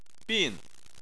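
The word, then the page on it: bin: tone 4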